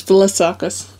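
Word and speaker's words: Vlassakis: I'm pretty sure it's Vassakis.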